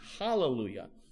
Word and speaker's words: hallelujah: The word alleluia with an A, the Greek language doesn't have an H. It has a H sound that starts words with vowels. Okay, that's why alleluia would be pronounced actually hallelujah, if they were pronouncing it that way.